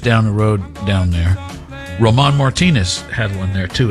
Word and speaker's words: Roman: He does not pronounce it Ramon. He's not R-A-M-O-N. He's R-O-M-A-N. He's Roman Martinez, just so you know.